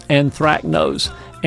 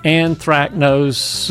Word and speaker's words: anthracnose: This is a very hard word to spell, but I can pronounce it very nicely. It's anthracnose. Anthracnose, A-N-T-H-R-A-C-N-O-S-E, but anthracnose is a spotting disease.